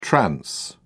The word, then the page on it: trans: British English